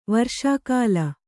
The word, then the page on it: ವರ್ಷಕಾಲ: varṣa kāla